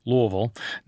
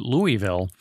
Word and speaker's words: Louisville: And so that's when I learned to pronounce Louisville like a native, Louisville, not moving your mouth, swallowing all the vowels, which is how you know someone pronouncing it Louisville is not from there.